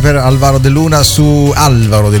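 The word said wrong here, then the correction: Álvaro: Luna, anzi l'accento sulla prima A, Alvaro.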